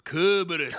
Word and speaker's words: Kerberos: they pronounce Cerberus with a K. Whoa. – They all fucking do it. Hard K, like No, but- special K, baby. – Yeah. Cerberus. Kerberos. – Ah, Kerberos.